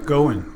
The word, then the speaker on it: Goin: Goin. Akil Goin. Going everywhere, yeah.